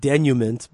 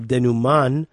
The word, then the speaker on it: denouement: It's spelled D-E-N-O-U-E-M-E-N-T, like denouement, but really it's denouement or denouman, which is the final, yeah, I think it's with an N at the end, denouement,